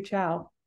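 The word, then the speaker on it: chao: They all say ciao.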